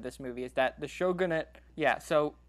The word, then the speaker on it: shogunate: which led to the Tokugawa shogunate being created. – Oh, shogunate is how you pronounce it.